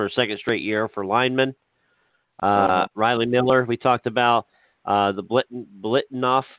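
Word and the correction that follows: Biletnikoff: – I said that, I always say that wrong. Blitnikoff Award for wide receivers, best wide receivers list.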